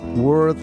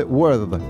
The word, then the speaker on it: word: The World as Law. La palabra, como leí, no el mundo, ¿eh? The word, no world. Es una ligera diferencia en la pronunciación.